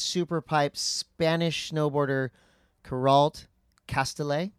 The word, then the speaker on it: Castellet: I say that right? – Castellet. – Castellet? – Yep. – Oh, because in... Is she from the Basque country? – Yes, hard okay. – tea. – Hard tea. Noted.